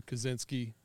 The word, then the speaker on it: Kazenski: my name's Drew Kazenski,